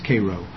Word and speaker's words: Cairo: But in Egypt it's Cairo, but in Illinois it's Cairo. Spelled the same though.